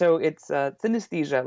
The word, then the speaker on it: synesthesia: There's actually an A in the middle of the word, so you mispronounce it like synesthesia. – Yeah. – But it's synesthesia, like anesthesia, but uh-huh.